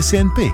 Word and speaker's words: CNP: Si legge S&P, si scrive CNP.